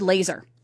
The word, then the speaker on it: Lazar: I'm going to say David Lazar. I kind of like that. It just sounds fun. It's L-A-Z-A-R, so maybe Lazar.